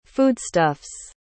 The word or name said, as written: foodstuffs